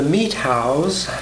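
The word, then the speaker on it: Methow: Actually, up until, well, I guess nominally last year, not much had ever been written about the Meethows, and that's the way they pronounced it apparently, the Meethows, the Entiats, the Chelans, and the Wenatchees as distinct groups.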